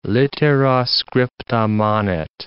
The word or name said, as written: littera scripta manet